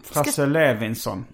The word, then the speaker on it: Frasse Levinson: Frasse Levinson. – Precis. – Är det så han uttalar sitt namn? – Ja, det är det.